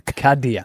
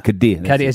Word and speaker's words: Kadir: Kadir. Kadir as in Cardinia Park.